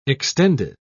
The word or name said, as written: extended